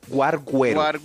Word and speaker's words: guarguero: A mí me respeta, por favor, porque se me queda la bandeja paisa entre el guarguero. Guarguero. ¿Sí se dice así? – Sí, claro. – Guarguero. – Guarguero. – Muy bien dicho.